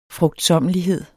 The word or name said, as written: frugtsommelighed